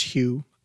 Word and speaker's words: Huw: That's H-U-W. And I assume it's pronounced Hugh.